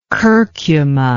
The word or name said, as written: curcuma